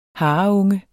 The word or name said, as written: hareunge